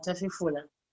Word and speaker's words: Tafifula: So this one is Tilo Tafifula. Did I pronounce that right? – Yeah, Tafifula. – Okay, Tafifula. So we're actually going to get into this song.